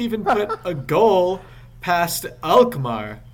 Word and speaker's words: Alkmaar: We failed to score against AZ Alkmaar, or however you pronounce that, a Dutch team. At least you pronounce Alkmaar right, and you pronounce Rochdale wrong, which is surprising.